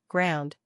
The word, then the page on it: Ground: electrical